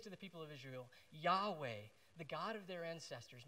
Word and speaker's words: Yahweh: But the Hebrew is often pronounced something like Yahweh, and it's built on the word I am.